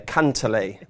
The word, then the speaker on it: Cantlay: I like, Steve, I very much like the Paul McGinley pronunciation there. Cantlay. That is it.